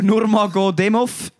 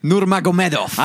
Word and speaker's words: Nurmagomedov: Khabib Nurmagomedov. – Nein, falsch. – Also gut, sagst du, komm. – Nurmagomedov. – Ah, Nurmagomedov.